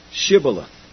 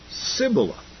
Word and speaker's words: Shibboleth: And if he said, No, then they said unto him, Say now, Shibboleth. And he said, Sibboleth. They couldn't pronounce the S-H.